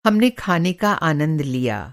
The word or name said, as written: आनंद